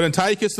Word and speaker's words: Antiochus: So remember Antiochus. Remember that. That's pronounced right. But Antiochus the IV, Epiphanes of the Seleucid Empire.